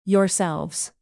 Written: yourselves

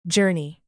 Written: journey

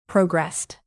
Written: progressed

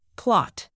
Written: plot

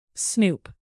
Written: snoop

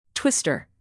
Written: twister

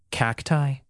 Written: cacti

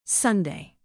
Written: sunday

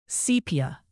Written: sepia

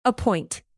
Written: appoint

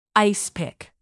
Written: ice-pick

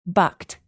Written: bucked

